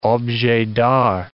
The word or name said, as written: objets d'art